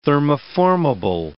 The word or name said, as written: thermoformable